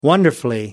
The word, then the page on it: wonderfully: American English